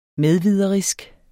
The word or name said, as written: medviderisk